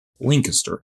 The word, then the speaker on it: Lancaster: Oh, so I did say it right the first time. – Yeah, pretty close. You want as few syllables as possible. – Lancaster.